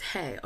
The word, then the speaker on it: hej: It's just hey, although I've been pronouncing it incorrectly. Because it's spelled H-E-J, so I've been saying hej like an idiot, but it's just hey, which I find hysterical.